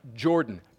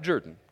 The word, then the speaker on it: Jordan: And by the way, that word is pronounced Jordan. Jordan. Jordan. Not Jordan.